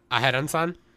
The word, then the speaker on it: Aharen: I can't pronounce this girl's name. Aharon. – Aharon-san.